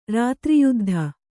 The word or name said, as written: ರಾತ್ರಿಯುದ್ಧ